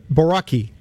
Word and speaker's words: Borucki: It was a dream come true, pitching in his hometown of Chicago against the White Sox, and it's Ryan Borucki. Did I say that right? – Yeah.